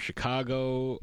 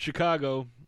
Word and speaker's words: Chicago: And I know some of you be like, oh, but you said Chicago. Chicago starts with a C. Just saying. Doesn't have a hard C at the beginning. It has a sh, but it's a CH, so Google your maps.